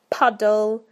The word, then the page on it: puddle: British English